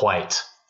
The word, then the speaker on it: white: And you have to pronounce it white with the H.